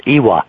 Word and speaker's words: Ewa: It's E-W-A, but people who don't know Hawaiian think that spells Ewa, but it's Ewa. The W is pronounced.